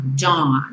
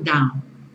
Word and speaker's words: dawn: Esse AU você vai falar como se fosse um O bem nasal. Dawn, não down. Dawn.